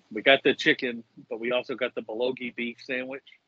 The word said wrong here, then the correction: bulgogi: The bulgogi? – Yes, that.